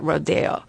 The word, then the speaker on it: Rodeo: Now, this ballet was not written for Martha, but I asked this artistic director, how do you pronounce, is it Rodeo or Rodeo? Because I've heard it both ways, mm-hmm. and she said Rodeo.